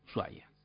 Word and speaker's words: Ushuaia: Ushuaia es una excepción en la pronunciación del idioma. Es la única palabra que se escribe con SH, pero que se pronuncia como una S. Ushuaia.